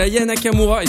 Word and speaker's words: Aya Nakamura: Mais juste avant, c'est Aya Nakamura, et cette fois je le prononce bien, qui arrive avec Copine sur Studek Web.